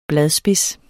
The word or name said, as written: bladspids